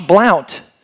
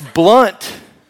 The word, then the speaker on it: Blount: But one of the things I run into from time to time is people will call me Josh Blount. Because my last name is spelled B-L-O-U-N-T. But you don't say Blount. It ain't Blount. Listen, everybody. It ain't Blount. It's Blunt. Blunt. Like my personality.